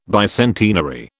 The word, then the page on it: bicentenary: British English